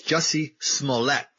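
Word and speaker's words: Jussie Smollett: I just learned how to pronounce it the other night, so I'm like ahead of the game. It's Jesse Smollett.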